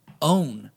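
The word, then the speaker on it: OWYN: It's called OWN.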